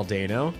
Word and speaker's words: Dano: And he From Paul Dano.